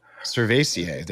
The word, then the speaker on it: cerevisiae: And that's Saccharomyces cerevisiae. And I'm probably saying that wrong. Cervisiae. – Cervisiae. – There you go.